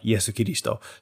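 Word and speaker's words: Yesu Kiristo (イエス・キリスト): And even if they were Christian, that's not how you pronounce Jesus in Japanese. It's pronounced like Latin, like Yesu Kiristo.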